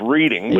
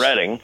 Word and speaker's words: Reading: Oh, well, that would be take a ride on the Reading, often mispronounced Reading, but yes. – Reading.